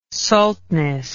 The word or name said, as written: saltness